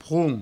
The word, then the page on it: pron: proun; provençal